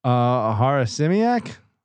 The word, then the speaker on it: Harasymiak: How do I pronounce this one? Harazimek. I can't pronounce any of these names on this team. – Harasimiak. – There you go.